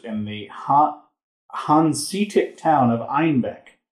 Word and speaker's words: Einbeck: Due to their Bavarian accent, citizens of Munich pronounced Einbeck as Einbach, a billy goat, and thus the beer became known as a Bock.